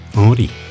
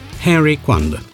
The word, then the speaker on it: Henri: În urmare, pronunția corectă a prenumelui este cea franceză, Henri. Numai că, din neștiință sau din presupunerea nefondată că prenumele savantului ar fi fost britanic sau american, cu toate că asta ar fi subînțeles prezența unui Y final în locul I-ului, destui vorbitori îl pronunță greșit Henry Coandă, ca pe Henry Kissinger, Henry James sau Henry Miller.